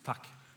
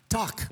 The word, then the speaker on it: Takk: How do you say thank you? – Takk. – Talk, yeah. – Just talk. – Yes. – Talk? Mm-hmm. Not tea.